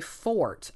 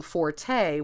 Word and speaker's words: forte: When you're talking about someone's strength, you typically say fort. I mean, the accepted or the correct, in quotes, word would be fort for that pronunciation, although nowadays they're allowing forte, which is, as you said, the musical term.